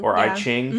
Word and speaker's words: I Ching: I Ching, mm-hmm.